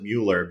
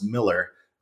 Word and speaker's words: Mueller: A lot of people pronounce it Mueller because it looks like Mueller. But when I was here early in the 90s, I interviewed the family and they said, no, it's Miller, but it doesn't matter.